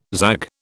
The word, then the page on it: zag: British English